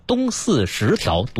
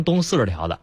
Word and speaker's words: 东四十条: 先把东四十条读对了，知道吗？ – 东四十条，对、哎、但凡读成东 40条的，这得从头选，知道吗？